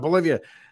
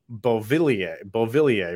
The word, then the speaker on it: Beauvillier: Bolivia. But it's Beauvillier, I believe is how to pronounce it.